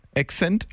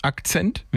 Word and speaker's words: Accent: Ein Hyundai Accident. Nee, Accent, Akzent. Wir wussten nie, wie wir es aussprechen sollen. Aber hinten auf der Kofferraumklappe stand Enceinte. Enceinte? Accent.